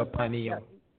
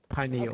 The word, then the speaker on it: pineal: And now, how did you pronounce it? You said it differently than I did. – It's a pineal. – Pineal gland. – Pineal. – Okay.